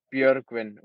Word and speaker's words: Björgvin: So I would say it's Bjorgvin.